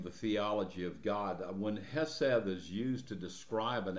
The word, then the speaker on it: hesed: One of the most important words in the Hebrew Bible is a word that is pronounced in the Hebrew hesed, and it's the word mercy in the text before us.